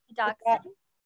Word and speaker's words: Dachshund: Am I saying that right? – Dachshund. – Dachshund? okay, that's embarrassing. All the do- I'm I am a dog person, but apparently I can't say that word.